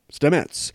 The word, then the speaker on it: Stamets: is based on real-life mycologist Paul Stamets. And that's how you pronounce his name. Now I know how to pronounce his name.